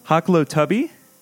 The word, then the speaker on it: Hoklotubbe: I'm very excited this week to have on my guest, Chris Hoklotubbe.